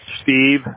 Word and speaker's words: Steve: Steve Young. It's S-T-E-V-E. And the last name is Young. Y-O-U-N-G.